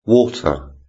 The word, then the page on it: water: British English